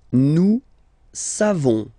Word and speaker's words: savons: Nous savons. So classic ending for nous, O-N-S. You don't pronounce the final S.